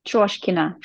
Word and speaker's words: Troshkina: Troshkina.